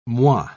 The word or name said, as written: moi